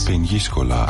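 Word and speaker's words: Peñíscola: Aber ich vermute, es spricht sich anders aus. – Peniscola. – Ah, Peniscola, ja, das ist natürlich was ganz, ganz anderes.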